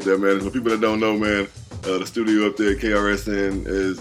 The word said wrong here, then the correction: KSRN: did I say that right? Did I get a call? Did I get the call letters right? – KSRN. – KSRN. Okay, good.